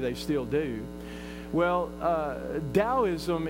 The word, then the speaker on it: Taoism: I looked at one other religion called Taoism, and it's pronounced with a D, Taoism, but it's pronounced with a T, T-A-O-ism.